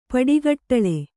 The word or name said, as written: ಪಡಿಗಟ್ಟಳೆ